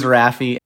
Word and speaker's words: Raffi: So my whole life I was saying Rafi. I was not saying Rafi until you told me how to properly pronounce this guy's name. So thank you. – Yeah, you're welcome. I said Rafi as well. So I learned right before meeting him that I was saying his name wrong. So you're not alone. But yeah, it is Rafi.